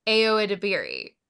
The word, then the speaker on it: Ayo Edebiri: Her name is pronounced Ayo Edebiri.